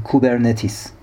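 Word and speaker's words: کوبرنتیس (κυβερνήτης): ریشه کلمه سایبرنتیک یک واژه یونانیه با تلفظ کوبرنتیس.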